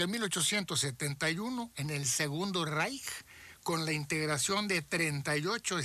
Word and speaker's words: Reich: Sí, más nada más para la gente como usted lo pronunció correctamente en alemán, dijo Reich.